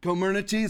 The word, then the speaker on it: Comirnaty: Co- Comer, what's the real way to pronounce it? Co- Co what? Comirnaty. It's like Comirnaty, the one that the, the vaccine.